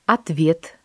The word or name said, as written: ответ